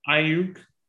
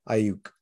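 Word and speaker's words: Aiyuk: Ayuk. – Ayuk, okay.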